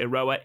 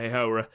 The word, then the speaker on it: Iroha: We are going to do our best to pronounce it Iroha in... If all else fails, we'll just call her Takumi, because I'm still calling her Hihora.